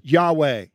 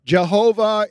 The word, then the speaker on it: Yahweh: Most scholars today believe the proper pronunciation is Yahweh. Jehovah is an alternative pronunciation. It kind of depends on whether you use Latin to transliterate these letters or not.